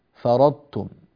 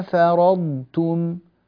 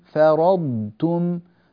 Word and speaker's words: فرضتم: الخطأ الذي قد يقع في مثل كلمة فرضتم، كلمة عرضتم، أن تتحول الضاد إلى طاء، فرضتم عرضتم، هذا غير صحيح.